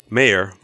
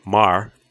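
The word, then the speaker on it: Meagher: I'm glad you mentioned that because the place to eat meals, Coach Sampson told us, even the TJ Mayer, Mar, they heard two pronunciations of it. It's M-E-A-G-H-E-R today during the tour,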